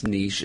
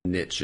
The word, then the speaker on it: niche: Well, it's a French word, and so in French it's niche, and so it's a question of is that a word that has been fully Americanized? We pronounce it the American way, or do we still do it the French way? And I believe that the pronunciation experts say that that word has been Americanized, and so we niche is fine.